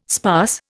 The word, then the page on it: sparse: Indian